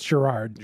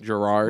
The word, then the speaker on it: Gerard: Do you think that's how that name's pronounced? Gerard? What do you? – I'm sure it's Gerard. – Gerard, which is, you know, if you've ever met anybody named Jerry, that's what it comes from.